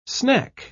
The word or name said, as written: snack